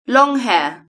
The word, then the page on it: long hair: British English